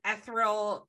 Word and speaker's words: ethereal: ethereal, I can never say this right. – Ethereal. Ethereal. Ethereal quality.